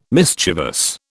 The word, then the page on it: mischievous: British English